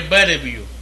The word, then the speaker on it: Bellevue: La calle Bellevue, se escribe Bellabue, 307, en Villa Palmera, Santurce.